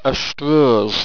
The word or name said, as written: astroze